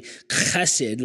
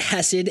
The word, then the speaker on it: hesed: And the word is the Hebrew word hesed. And that's how we say it. It's I think it's actually chesed, like one of those kind of things where they when they say it.